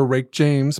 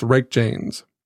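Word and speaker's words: Reykjanes: The lake is located on the Rick James Peninsula. – Rick James, bitch. – That's what I thought. I looked up how to pronounce it. I was like, is it Rake James? And it's Rake James.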